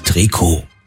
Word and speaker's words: Trikot: Jahrhundert setzte sich der Begriff für eng anliegende Sportkleidung durch, weil diese eben aus dem dehnbaren Strickstoff hergestellt wurde und weil im Französischen in der Regel die letzte Silbe betont wird, heißt es richtig Trikot.